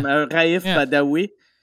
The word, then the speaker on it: Raif Badawi: Raif Badawi.